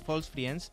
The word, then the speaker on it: false friends: Tampoco lo pronuncias tú muy allá. – False friends, lo he dicho perfecto, para una vez que lo digo bien.